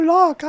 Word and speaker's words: Locke: Oh, Locke, I'm sorry. – Okay, that's better. Yes, perfect. You nailed it.